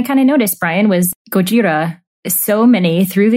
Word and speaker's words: Gojira: And on today's show, I'll be insisting on the pronunciation Gojira.